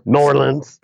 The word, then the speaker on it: New Orleans: New Orleans.